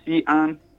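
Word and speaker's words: Xi'an: Sion. – Sion. So an X sounds like a C? – It kind of sounds like an S with more air through it.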